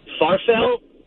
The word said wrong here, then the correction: farfalle: That's not a pasta, man. Are you talking about farfalle, man? – Yeah, farfalle. – You know what he meant. – I pronounce it wrong.